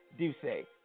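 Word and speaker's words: D'USSÉ: D-U-S-S-E. How do you pronounce that shit? Don't laugh, pronounce it. Doce. It's called Doce. It's French, so you got to say it like that. Doce.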